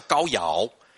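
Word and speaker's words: 皋陶: 那黄姓的话呢，出自于嬴姓哦，是古帝少昊之后，少昊的后代呢，有一位叫高尧，好、哦，这个陶啊。在这个地方要破音念作尧，好，这个是多音字哈。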